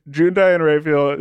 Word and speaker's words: June Diane Raphael: June Diane Raphael as The Bachelorette in the second season of that is like one of the funniest.